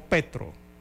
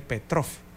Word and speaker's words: Petro: Y es que el presidente de Colombia, eh, Gustavo Petro, ahora sí lo pronuncié bien, ayer me dijeron que dije Petrov. Bueno, es Gustavo Petro.